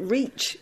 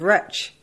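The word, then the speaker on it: retch: I love that you pronounce it reach instead of wretch. Is that a Newport Pagnell thing? – Well, that's how my parents pronounce it, which would suggest it's a London or an Irish thing.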